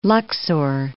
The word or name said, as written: Luxor